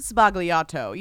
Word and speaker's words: sbagliato: Sbagliato, you know? – Negroni sbagliato. – Sbagliato. See, it's not Sbagliato as they said. – No, there's no it. – G. It's you don't sbagliato. Pronounce the G.